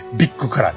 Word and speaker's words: VIC: ビッグカラーね。 – ビッグカラーです。 – はいはい。 – VIC と書いてビッグと読むってね。